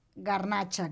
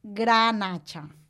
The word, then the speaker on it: garnatxa: En català és garnatxa blanca, garnatxa negra, garnatxa peluda, garnatxa gris, però en cap cas és granatxa. – Tot i que aquí molt sovint diem granatxa, eh? – Sí. – Tot s'ha de dir. – Sí, sí, sí. La pronunciació d'aquí és granatxa, però no és correcte.